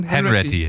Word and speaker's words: Hanratty: Henretti, wie wird es ausgesprochen? – Henretti, ja.